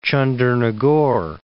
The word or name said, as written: Chandernagore